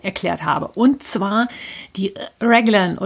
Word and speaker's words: Raglan: Also die richtige englische Aussprache wäre Lord Raglan. Demzufolge heißt es auch englischsprachig richtig Raglan oder Raglan Sleeves oder Raglan Construction oder wie auch immer.